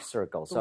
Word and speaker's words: circle: 이 circle, 이거 쉽지 않아요. 왜냐하면 얘는 r도 있고 l도 섞여 있죠. – 예. – 혀가 뒤로 당겨졌다가 앞으로 금방 뻗어야 됩니다.